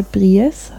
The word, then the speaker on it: Brice: Das also da fehlt der Akzent, aber eigentlich müsste der mehr Bries heißen.